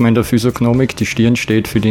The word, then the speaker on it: Physiognomik: Und was ist, du hast auch aufgeführt, Physiognomik, habe ich es richtig gesagt? – Ja, Physiognomik.